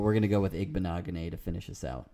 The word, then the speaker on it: Igbinoghene: Igbinoghene? Igbinoghene. That's how you pronounce it.